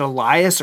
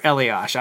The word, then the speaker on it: Elias: But I saw, I think Adam Schefter mentioned this and it was from, I don't know, is it Elias or Elias? I always pronounced it Elias because that's how the hockey player pronounced it, but it might be Elias. Sports Bureau. – I say Elias.